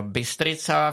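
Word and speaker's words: Bistrița: Ono se sice píše st s takovým ocáskem, ale čte, čte se Bystrica,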